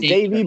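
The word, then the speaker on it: Domingo: Happ or Devy Garcia, or how do you say his first name? I always say it wrong. Not Devy. – It's Davey, but I'm calling him Devy.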